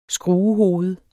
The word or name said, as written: skruehoved